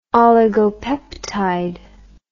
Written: oligopeptide

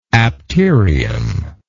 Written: apterium

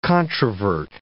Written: controvert

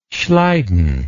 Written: schleiden

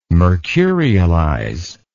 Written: mercurialize

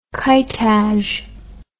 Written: Kitaj

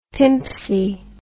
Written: pimpsy

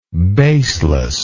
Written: baselessness

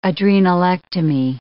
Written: adrenalectomy

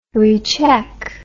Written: recheck

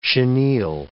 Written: chenille

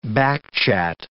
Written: backchat

